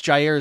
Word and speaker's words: Jair: Next up is Jair, I think it's Jair is the best pronunciation I can find for it, Brown, Penn State product.